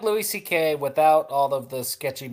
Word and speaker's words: Szekely: And so his last name is normally spelled S-Z-E-K-E-L-Y, but that's pronounced C-K in Polish.